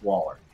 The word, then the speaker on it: Waller: And Argus and their, well, Amanda Weller, or Wheeler, whatever the hell you pronounce her last name, is going to be like, yeah. – Or actually, it's Waller.